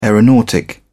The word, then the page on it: aeronautic: British English